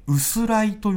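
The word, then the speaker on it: 薄氷: うんそんな春先の薄く張った氷のことを、薄い氷と書いて、薄らいと呼んでいます。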